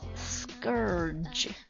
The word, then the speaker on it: scourge: 这个单词呢是我在看那个特种部队什么忘记那电影名字了，看听到的，读作 scourge scourge scourge s c o u r g e。我刚开始看到这个单词的时候差点把它看成了 scourge，给它在里面硬生生的加一个字母 a，不是的，它读作 scourge。